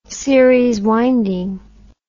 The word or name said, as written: series winding